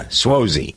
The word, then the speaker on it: Suozzi: On the people list, Tom Suozzi, is that how you pronounce his name, Jen? Suozzi? – That's a new congressman. – Yeah, Suozzi.